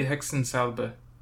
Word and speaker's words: Hexensalbe: In German it was Hexensalb. – Hell yeah. Cause I probably said that wrong. – It would be Hexensalbe, probably.